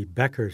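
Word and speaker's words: Becher: Actually, the correct German pronunciation would be Becker Street. I don't know why we pronounce it Beecher Street.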